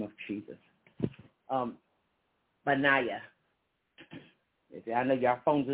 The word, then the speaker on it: Benaiah: Benaiah. B like in baby, ba. The E is really silent. I'm going to spell it B-E-N-A-I-A-H. Benaiah. And then the E again is silent. B and Naya. N-A-I-A-H.